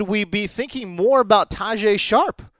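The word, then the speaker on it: Tajaé Sharpe: Tajay Sharp.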